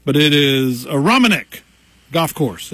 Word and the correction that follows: Aronimink: Did I get that right? A Romanek? Is that how you say that? – Aronimink. – Aronimink. See, I'm from McDowell County, Pete. You can't throw words like that at me and me say it right. So, but you nailed it.